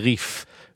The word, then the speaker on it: Rif: It comes from Rabbi Yitzchak Alfasi, or the Rif, which is actually really apropos, because when pronounced with a good Hebrew accent, it's the Rif, which is, you know, the nautical theme continues.